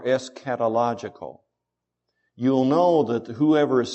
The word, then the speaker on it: eschatological: The first, if you're taking notes, the first goal is eschatological. I know it looks like eschatological, but it's pronounced eschatological as if it was a K and not a CH. Eschatological.